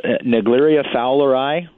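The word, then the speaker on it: Naegleria fowleri: Is going on with this thing, and how do you really say it? Negleria fowleri. – I believe it's Negleria fowleri, believe is how you pronounce it.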